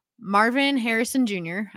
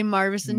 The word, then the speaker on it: Marvin Harrison Jr.: Marvin Harrison Jr. I said it correctly. I did not say Marvinson.